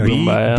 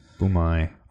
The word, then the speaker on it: Bumaye: Ollie Boombay. – B-U-M-A-Y-E. – He's going to crucify us for knowing this. – Boombay.